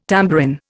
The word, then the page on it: tambourin: Indian